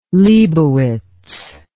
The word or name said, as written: leibowitz